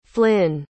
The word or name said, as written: flyn